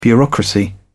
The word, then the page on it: bureaucracy: British English